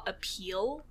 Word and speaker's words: Apeel: So my first one is something called a peel. So that's spelled A-P-E-E-L. So literally, like, you know, A and then peel as in the peel of a fruit or vegetable.